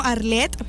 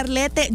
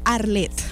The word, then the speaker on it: Arlette: Me llamo Arlette, pero se escribe Arlette, ¿ok? Aunque Barba venga y me diga Arlette, yo soy Arlette, ¿ok?